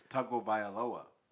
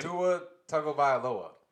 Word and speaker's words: Tagovailoa: Tagovailoa. – Say it again. Say it again. – Tua Tagovailoa.